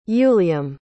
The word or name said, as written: uilliam